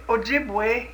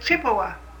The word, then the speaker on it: Ojibwe: But the Englishman couldn't say it Ojibwe, he said Chippewa.